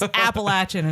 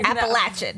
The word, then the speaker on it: Appalachian: It's Appalachian, and everyone who's listening, now you know. – Appalachian.